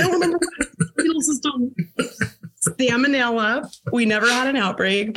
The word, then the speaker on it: salmonella: Salmonella, I just found out I've been saying that word wrong, and now I said it wrong again. It's salmonella. It's not like the fish.